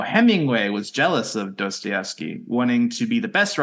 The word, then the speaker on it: Dostoevsky: You listened to the pronunciation and still said it wrong. – Dostoevsky. – Play the pronunciation. – Dostoevsky. – Okay. I guess you said it right.